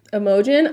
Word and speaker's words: Imogen: Now, I'm going to spell the name for you, and I want you to tell me how you would pronounce it. – Oh, boy. – I-M-O-G-E-N. – Imogen. – Imogen? – I wasn't sure. No, it's Imogen.